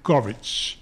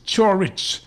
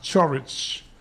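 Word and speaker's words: Ćorić: It is Chorich, spelled C-O-R-I-C, but pronounced Chorich.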